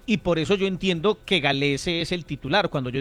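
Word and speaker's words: Gallese: Cuando Dudamel llegó, Galese dijo el mismo que se decía así, el mismo dijo que su apellido se pronunciaba así, Galese.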